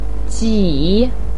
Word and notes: ji3: pinyin

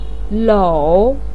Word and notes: lou3: pinyin

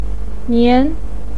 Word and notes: nian2: pinyin

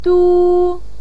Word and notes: du1: pinyin

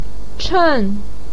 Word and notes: chen4: pinyin

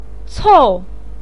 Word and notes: cou4: pinyin

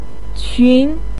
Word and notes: qun2: pinyin

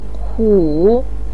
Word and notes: hu3: pinyin

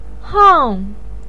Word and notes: hang4: pinyin

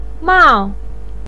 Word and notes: mao4: pinyin

